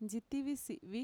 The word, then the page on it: ntxitivisi'vi: Mixtec